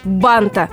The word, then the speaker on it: бант: Банта. Много бантов. Красные банты. В слове бант, так же, как и в слове шарф, всего один слог. И ударение при любых изменениях не сдвигается.